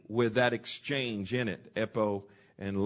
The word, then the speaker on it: epo: The next one is epo, E-P-O, long O, and it means just simply mainly to speak or to say.